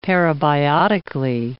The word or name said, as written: parabiotically